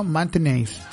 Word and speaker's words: Maintenance: Alonisis Garden Mantenéis. Lo dije bien en inglés, ¿cierto? Maintenance. ¿Ah? Garden Maintenance. Alonisis Garden Maintenance.